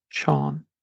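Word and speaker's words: Seán: It is also used as a translation of the English name John, and it's pronounced Sean. Next is Owen, E-O-I-N, which is said to be the actual Gaelic form of the name John, meaning God is gracious.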